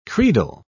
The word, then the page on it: credal: British English